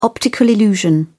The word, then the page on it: optical illusion: British English